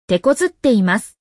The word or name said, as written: tekozutteimasu